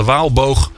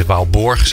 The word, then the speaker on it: Waalboog: En te gast in deze uitzending Took Piket van de Waalboog. En eh, nu zeg ik het goed, hè? ik zei het net helemaal, of ik zei de Waalborg, zo heette ze helemaal niet.